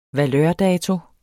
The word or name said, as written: valørdato